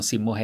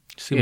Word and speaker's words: Simo: Och då uttalar jag det, det stavas ju S-I-M-O, mm. så jag hade uttalat det Simo. Mm. Men eh, ska, då fick jag ju mail direkt att det gör man inte utan simo. Simmo. Är det precis som med ja. Timo och timo.